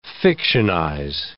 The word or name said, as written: fictionize